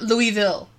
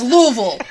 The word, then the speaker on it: Louisville: And I'm wanting on record, it's Louisville. We are not French. It is not Louisville. It's Louisville.